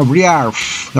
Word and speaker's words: Briargh: La banda se llama Briarf, así se pronuncia, en verdadero celta, ¿no? escocés. Briarf, termina en GH.